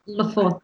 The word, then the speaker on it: Lofoten: So, you know, briefly, how was Lofoten, isn't it? That's how you say it, Lofoten, I think. – Lofoten. – Yeah. – Yeah. Yeah, that's the one.